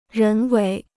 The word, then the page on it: 人为: rén wéi